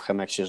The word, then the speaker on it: Chemex: Co jest takiego niesamowitego w Chemexie, że on tak zawojował świat? – Jakoś bardziej się wymawia Chemex.